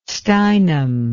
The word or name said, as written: steinem